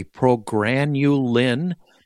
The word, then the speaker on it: Progranulin: I may mispronounce this, so please correct me. Progranulin. This is a perfect.